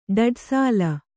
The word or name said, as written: ಡಡ್ಸಾಲ